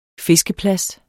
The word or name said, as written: fiskeplads